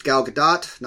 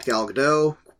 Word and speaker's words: Gadot: And yes, it's pronounced Gal Gadot, not Gal Gadot. Please quit doing that, everybody.